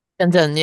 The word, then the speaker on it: Shenzhen: And forgive me if I pronounce it wrong, but it is really close to Hong Kong, but it's Shenzhen. – Shenzhen, yeah. Yeah.